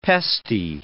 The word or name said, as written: pesty